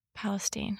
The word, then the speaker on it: Palestine: I say Palestine. – Got it. – Like the Middle East, it's Palestine.